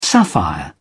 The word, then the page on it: sapphire: British English